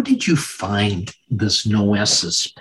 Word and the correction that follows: noesis: Uh, am I pronouncing it properly? – Well, uh, because of the way you're pronouncing it, that's why we put the uh, that's why we put the bar over the e because it's very easy to mispronounce. Um, it's noesis, um, and that's a Greek word that means um, the arrival place of intelligence and understanding.